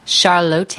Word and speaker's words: Charlotte: Did I say it right? – Charlotte. – Charlotte. – Yeah. – Okay. – Whole lot of lotte. – Emphasis on the low.